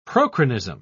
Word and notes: prochronism: British English

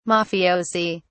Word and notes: mafiosi: British English

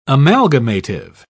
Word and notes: amalgamative: British English

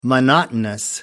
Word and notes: monotonous: American English

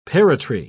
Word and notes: parrotry: British English

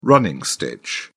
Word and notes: running stitch: British English

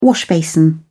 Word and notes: washbasin: British English

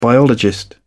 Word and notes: biologist: British English

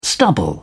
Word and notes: stubble: British English

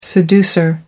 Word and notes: seducer: American English

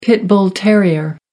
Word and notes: pit bull terrier: American English

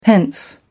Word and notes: pence: American English

